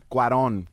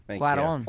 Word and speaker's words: Cuarón: Cuarón. – Cuarón.